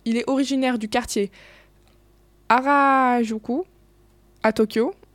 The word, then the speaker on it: Harajuku: Euh, euh, Harajuku.